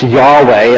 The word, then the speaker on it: Yahweh: Now, you know the word in Hebrew for the Lord, the special word, we pronounce Yahweh, and it's tricky because they didn't pronounce it at all, but it means, you know, the Lord, personally the Lord.